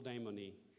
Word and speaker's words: Damon: Locally, I'm known as Uncle Damony. I think they put an E on the end of my name because my name Damon, the way we pronounce it here, is the way they pronounce demon.